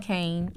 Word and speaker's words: Cain: I'm on Facebook, Darlene Kane, and that's D-A-R-L-E-N-E, last name is spelled Kane, C-A-I-N.